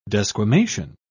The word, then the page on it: desquamation: British English